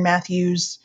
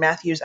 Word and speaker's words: Mathues: Um, I'm also on Instagram at Lauren Mathues. I'm on threads, Lauren Mathues. And my name is spelled a little funky. the last name is spelled M A T H U E S.